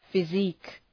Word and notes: physique: fı’zi:k

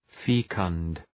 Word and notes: fecund: ‘fi:kənd, ‘fekənd

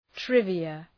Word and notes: trivia: ‘trıvıə

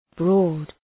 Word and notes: broad: brɔ:d

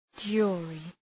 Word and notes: jury: ‘dʒʋrı

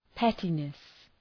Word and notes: pettiness: ‘petınıs

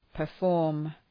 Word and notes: perform: pər’fɔ:rm

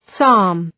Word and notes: psalm: sɑ:m

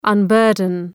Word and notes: unburden: ʌn’bɜ:rdən

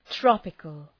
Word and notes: tropical: ‘trɒpıkəl

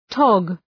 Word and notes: tog: tɒg

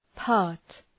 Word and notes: part: {pɑ:rt}

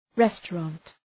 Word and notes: restaurant: ‘restə,rɒnt

